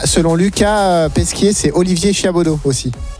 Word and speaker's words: Chiabodo: Quand tu écris Chiabodo. – Robert. C-H-I-A-B-O-D. – Non, c'est Chiabodo. – Comme ça se prononce.